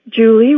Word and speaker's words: Julie: Julie rhymes with unruly and yours truly.